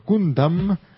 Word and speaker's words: Gundam: Y por último, mi fanatismo por Gundam, más en Puramoderu, me hace pedirles esta aclaración. Su pronunciación es Gundam, con A.